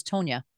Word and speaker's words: Tanya: It's actually Tanya Wallum. I said it wrong. Is it Wallum? – No, Wallum is right. The first name is Tonya. – Tonya. – Yes. Oh, yes. – That's why it's spelled that way.